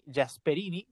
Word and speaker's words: Gasperini: Es lo que yo vi y creo que hoy eh, Giampiero Gasperini, Gasperini, como le dicen aquí en Italia, em, porque es la pronunciación, em, ha, ha, sabido tácticamente darle igual y un repaso con 10 hombres a las pretensiones blancas.